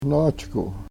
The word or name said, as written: nohchkuh